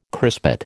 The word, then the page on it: crispate: American English